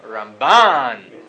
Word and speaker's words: Ramban: Rambam, the stress is on the first syllable. Rambam, mil el, and then the Ramban, mil ra, it's on the second syllable. Rambam, Ramban.